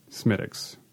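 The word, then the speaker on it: Smithwick's: But when I'm feeling like treating myself, when I uh, when I feel like I've earned myself a treat, I'm drinking Smittix, which is it's smell it's spelled Smith Wicks.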